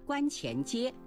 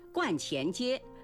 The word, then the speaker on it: 观前街: 年轻的时候到苏州旅游，去了一个繁华的地段，当时我叫它观前街。若干年以后才知道观是个多音字，还可以读去声观，指道教的庙宇，如道观、白云观。观前街是苏州的百年商业老街，因玄妙观而得名，